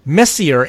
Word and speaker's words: Messier: It's also located in a galaxy called Messier 87. Is it really Messier or Messier? I would say it Messier. Although it's French, it's pronounced as Messi. So, okay.